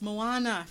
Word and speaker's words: Moana: I say Mona. I was like, yes, it's Moana.